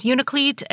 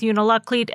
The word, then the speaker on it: Unalakleet: A lot of people pronounce the name of this town as Unalakleet and leave out the middle syllable, which is also an acceptable way of saying that.